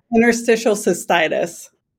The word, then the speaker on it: Interstitial cystitis: I know I said that wrong. Cystitis? No, how do I say that? – Interstitial cystitis.